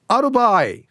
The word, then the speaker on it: あるばーい: A、えっとね、ある場合、かっこ、丸場合のイントネーションです。